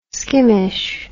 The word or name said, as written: skimish